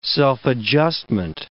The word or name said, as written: self-adjustment